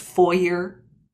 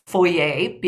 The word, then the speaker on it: foyer: And she called me one day and she said, "Hun, it's pronounced foyer." And I was saying foyer because I'm not French Canadian, but I do have that sort of, you know, understanding of a word that is of French origin, which foyer is.